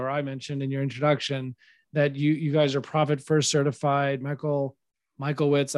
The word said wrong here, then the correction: Michalowicz: I don't know if I'm pronouncing that right. – Michalowicz. – Yes. Thank you. The wrong emphasis on the incorrect syllable, but what is the Profit First model?